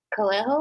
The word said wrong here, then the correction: Cabello: or Cabello. There's like two, Cabello. right? Cabello. I love that.